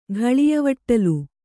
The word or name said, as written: ಘಳಿಯವಟ್ಟಲು